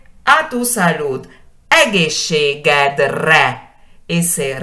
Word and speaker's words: egészségedre: Y si cambio el posesivo, digo egészségünkre. Ese cambio es porque ahora digo a nuestra salud. Egészségünkre, egészségedre, tu salud egészségünkre, nuestra salud, ¿no? A nuestra salud. Y siempre enfatizando la primera sílaba.